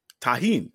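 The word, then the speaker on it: Tajín: Tarjan? What do you call that? – Tajin.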